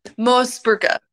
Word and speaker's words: Moosbrugger: But Freya, not Moosbrugger, not Moose. – Moosbrugger.